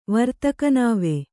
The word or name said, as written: ವರ್ತಕನಾವೆ